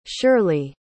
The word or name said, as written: shirlee